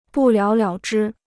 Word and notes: 不了了之: bù liǎo liǎo zhī

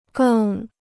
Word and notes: 更: gèng

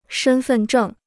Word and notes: 身份证: shēn fèn zhèng

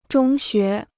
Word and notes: 中学: zhōng xué

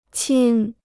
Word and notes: 亲: qīn

